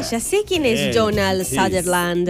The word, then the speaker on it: Sutherland: Sutherland.